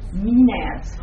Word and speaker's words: Maenads: Did you say the dancers were called Maenads? – Maenads. – Oh, Maenads.